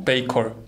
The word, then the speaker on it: Paycor: Paycor.